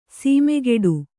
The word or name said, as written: ಸಮಕೂಟ